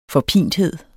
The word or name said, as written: forpinthed